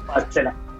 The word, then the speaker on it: Coachella: Coachella.